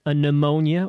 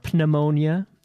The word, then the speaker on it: pneumonia: And I went back 2 weeks ago because my grandfather was in the hospital with a pneumonia, or a pneumonia, if you want to pronounce the P.